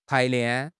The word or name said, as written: 排练